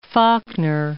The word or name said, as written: Faulkner